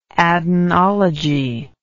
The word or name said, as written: adenological